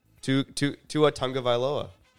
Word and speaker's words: Tua Tagovailoa: And then Tyreek Hill, there's risk because Tua Tagovailoa is his quarterback. – Yeah. – But he's Tyreek fucking Hill. I mean, damn, how did you say that name? – Tua Tagovailoa.